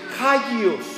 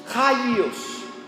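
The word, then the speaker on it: hagios (ἅγιος): que dice hagios, hagios, con J acento en la A, que quiere decir santo también.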